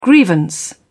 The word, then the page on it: grievance: British English